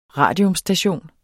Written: RADIUMSTATION

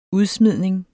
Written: udsmidning

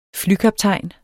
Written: flykaptajn

